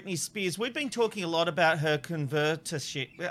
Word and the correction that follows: conservatorship: I can't even say it. How do you say it? – Conservatorship. – Conservatorship. Thank you very much.